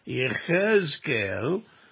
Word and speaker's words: Ezekiel: Now, during the Babylonian captivity, the second invasion, if you will, which was in 597 B.C., a Hebrew priest named Ezekiel, his name is pronounced Yechezkel, was carried away to Babylon as a prisoner of war.